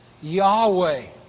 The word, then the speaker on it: Yahweh: That's what Yahweh means. He is. So you'll notice, he turns around and says, Verse 15, God also said to Moses, say to the Israelites, Yahweh, Y-A-H-W-E-H, Eyah, Yahweh.